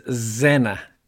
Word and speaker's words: Zennor: So I'm going to read a poem called, I believe the pronunciation is Zennor, Z-E-N-N-O-R, and if you live in Cornwall, you may have been there.